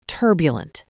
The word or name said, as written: turbulent